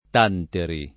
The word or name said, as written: Tanteri